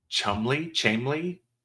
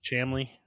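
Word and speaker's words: Cholmondeley: If I gave you the word that is spelt Cholmondely. – Okay. – I would then need to tell you that it's pronounced. – Tell me that's Chamley. Chomley.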